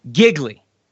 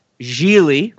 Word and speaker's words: Gigli: Giggly, or as it's truly pronounced, Gigli rhymes with really.